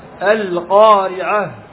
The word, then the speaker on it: القارعة: وأشد من هذا من يقرأ في الصلوات القارعة، ما هناك هاء، خطأ، القارعة لا بد أن تبين، لأنك أنقصت حرفا من حروف القرآن، وهذا لا يجوز، وهذا لا يجوز.